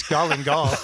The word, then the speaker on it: galangal: What the heck is galangal? – It's galangal.